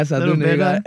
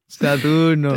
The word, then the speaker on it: Saturno: Saturno is the name of the album. Did I pronounce that okay? – Yeah, Saturno. – A little better. – Saturno.